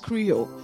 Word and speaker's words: chrio: It is spelled C-H-R-I-O, but it is pronounced krio.